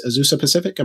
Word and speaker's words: Azusa Pacific: I also think of Dr. Alex Jun as well, who's a professor at, I believe it's Azusa Pacific.